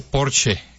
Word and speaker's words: Porsche: Se dice Porsche.